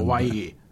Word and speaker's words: Hawaii: Hawaii diye okunuyor değil mi o da? – Sende. Hawaii. Dün evet.